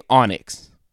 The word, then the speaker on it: Anax: I don't see pronunciation on here on this, but they're saying Onyx. – Right. – Like pronounce the A like ah, on X. Like, I guess that's how it's supposed to be done.